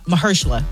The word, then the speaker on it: Mahershala: If you missed it this morning, Mashallah Ali stopped by Mahershala. Because that man pronounced his name 15 different times on that show this morning. And I still had the audacity to say it wrong.